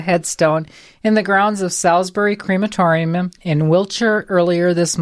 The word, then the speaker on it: Salisbury: Full marks, by the way, for getting the word Salisbury correctly pronounced. I'm impressed. You should get points. cool. – I was expecting you to say Salisbury.